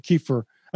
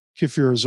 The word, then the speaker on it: kefir: And by the way, I kind of wince inside when I say kefir, but that's supposed to be the correct way to say it.